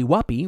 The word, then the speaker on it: Whoppy: Molly Whoppy, rhymes with puppy, likes to record them.